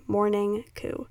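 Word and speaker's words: Mourning Coup: So I play Morning Coop on here a lot, and it's spelled M-O-U-R-N-I-N-G space C-O-U-P, Morning Coop.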